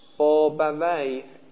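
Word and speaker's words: بابویه: بابه ویه، ابن بابه ویه میگن بابه ویه مبنی بر کسره.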